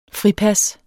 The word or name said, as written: fripas